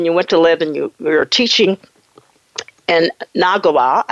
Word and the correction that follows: Nagoya: Some people just say Nagoya. – Mm-hmm. Nagoya? Is it right? Okay. – Nagoya.